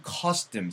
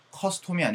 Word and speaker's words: custom: OM으로 끝나도 약간 M 사운드가 만들어지면 좋아요. wisdom이 아니고 wisdom 사운드가 만들어지면 좋고요. custom보다는 custom 사운드가 만들어지면 좋아요. 그래서 요것도 발음 한번 들려드릴까요? custom이 아니고 custom, wisdom 이런 식으로 사운드가 나야 돼.